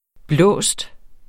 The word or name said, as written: blåsten